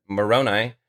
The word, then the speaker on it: Moroni: and it's pronounced exactly how I just said it, Moroni,